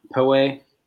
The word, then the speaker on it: Poway: They're like Poway, around Poway. Is that how you pronounce it? – Yeah, I think so.